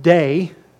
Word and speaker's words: dei: It stems from the Greek word, which is pronounced dei. It's spelled D-E-I.